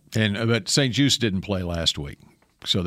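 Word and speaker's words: St. Juste: And one of their starting corners, how do you say it? Is it Juice? Just? St. Just? – Benjamin St. Juice.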